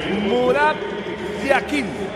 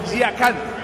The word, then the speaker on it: Yakin: En el suizo se pronuncia así: Murat Yakin. En turco, Yakan. Cambia la última parte de su apellido.